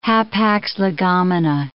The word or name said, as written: hapax legomena